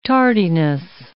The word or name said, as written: tardiness